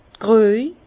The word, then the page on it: d'r Krüej: Bas-Rhin; Herrlisheim